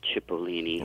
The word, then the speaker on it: Cipollini: Actually, you pronounced it, if we were in Italy, apparently it would be Cipollini. – Right. – Here, everybody, they turn into an S.